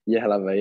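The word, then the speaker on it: Jihlava: Yihlava, yeah. Dukla Yihlava. Yeah, Dukla Yihlava. Yeah. So the J is silent, like in yogging. – Yeah, they... they say their J's are like a year there, so yeah.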